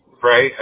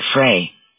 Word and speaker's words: Frey: Frey, F-R-E-Y. – R-E-Y. And you pronounce it Frey. – I say Frey. I mean, a lot of people say Fry.